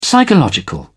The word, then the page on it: psychological: British English